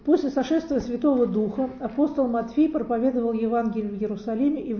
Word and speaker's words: Матфий: И, помолившись Богу, апостолы бросили жребий, и жребий выпал Матфий. И он стал полноправным апостолом. Родился он, тот был Матфей, а это Матфий, пишется по-другому.